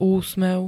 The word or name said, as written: úsmev